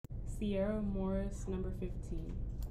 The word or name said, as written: Sierra Morris